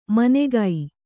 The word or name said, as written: ಮನೆಗೈ